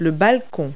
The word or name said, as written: balcony